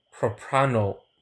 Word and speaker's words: propranolol: It's propanol or something, but it's something that I believe controls your kind of slows down your heartbeat, or slows down your heart. – So I would pronounce it propranolol.